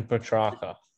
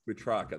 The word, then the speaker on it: Petrarca: I think Melbourne put a cricket score on the Footscray Bulldogs, and I think Petrarca. How do I say that, Bush? – Christian Petrarca. – Petrarca, that's it.